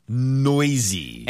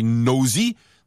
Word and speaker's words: noisy: Noisy. – N-O-I-S-Y. – Yeah, but now pronunciation is very important. Be careful not to say nosy.